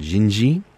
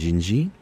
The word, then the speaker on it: Dindi: And this song is called Jinji, D-I-N-D-I, but pronounced Jinji, which is Brazilian for Jennifer or Genevieve, which is my wife's name.